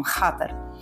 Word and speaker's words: Xatar: Se escreve Chatter, vocês estão vendo, né? O som é meio aspirado, assim, é um Shatter.